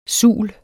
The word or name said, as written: sul